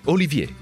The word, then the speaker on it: Olivier: Si pronuncia così alla francese, Olivier, perché la famiglia da cui discende è di origini francesi, giunta a Londra all'epoca delle persecuzioni degli Ugonotti.